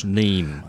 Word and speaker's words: Niamh: It's that crazy Irish name, N-I-A-M-H, Neem, I think that's how you pronounce it.